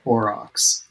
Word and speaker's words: Aurochs: It's called, I think it's the Aurochs. Do you think I'm pronouncing that correctly? – That sounds right. – All right, we'll go with Aurochs.